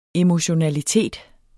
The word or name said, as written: emotionalitet